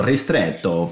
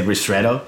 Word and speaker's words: ristretto: Technically you pronounce it, if you want to go Italian, you go ristretto, but otherwise we say ristretto.